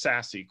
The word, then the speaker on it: SASE: Uh, you'll hear it pronounced sassy quite a bit.